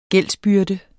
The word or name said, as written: gældsbyrde